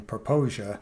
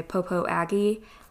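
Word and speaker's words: Popo Agie: Now, a lot of people that may not be familiar with the name Proposia, it doesn't quite look like Proposia when you could you spell that for sure. us, please? It's P O P O new word A G I E. So it, when we have visitors call, sometimes they'll say Popo Aggie, and we know what they're talking about.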